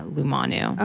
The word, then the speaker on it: Lumanu: So right now you work at Lumanu. Am I saying that correctly? Lumanu. Lumanu. – Yeah. Kind okay. of like illuminate. So you're you're kind of right, but yeah, Lumanu.